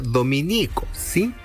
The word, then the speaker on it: Dominicos: Si se alude a los religiosos de la Orden de Santo Domingo, se escribe Dominico, sin tilde en ninguna I. Por lo tanto, es la estación Los Dominicos, la que queda en Las Condes.